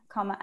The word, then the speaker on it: Kama: K-A-M-A, comma app. – K-A-M-A.